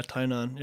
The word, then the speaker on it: Tainan: Your mom is from, I hope I'm pronouncing this correctly, Tainan? – Yeah, Tainan, yeah.